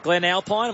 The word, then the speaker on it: Glen Alpine: Rob from Glen Alpine, am I saying that right? – Yep.